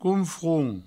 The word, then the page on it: confront(s): counfront